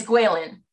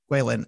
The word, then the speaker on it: Guilin: It's Guilin. – Guilin, and this is a mountain village.